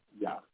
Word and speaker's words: Jah: And he tells us in Psalm 68, I believe, in 4, gives us a short version of his name, Jah, which would be pronounced Yah.